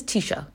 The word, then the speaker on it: Taoiseach: This is Taoiseach. That's what he is. Or Taoiseach, I should say. Some people pronounce it Taoiseach, but uh, I don't do that because that's not how my dialect pronounces it, because I'm from Donegal.